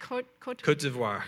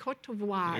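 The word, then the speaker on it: Côte d'Ivoire: So if you, so how do you say it? – Cote d'Ivoire. – Cote d'Ivoire. – Yeah, in English we'd say the Ivory Coast. – Cote d'Ivoire. – Cote d'Ivoire is the French name.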